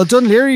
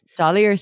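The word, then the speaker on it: Dún Laoghaire: Well, Dunleary would be pretty hard to pronounce for most people if you've never heard it before. Dunleary Street. – Dollier Street, I thought it was for years when I just saw it on maps.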